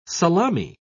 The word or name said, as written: salami